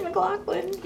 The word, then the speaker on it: McLaughlin: Did I say that right? McLaughlin. – The senior, yeah. – And you, I hear you out of the corner of my ear say, Judge McLaughlin, you know.